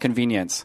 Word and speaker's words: Conveniens: It's spelled C-O-N-V-E-N-I-E-N-S. – Yeah. – But it's still pronounced Convenience.